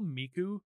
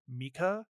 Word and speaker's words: Miku: The voice actress of Ginkgo can't pronounce things correctly to the point where she will call Miku Mika.